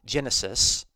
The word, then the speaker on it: genesis: So the Greek noun genesis, it's pronounced in modern Greek as genesis, means beginning.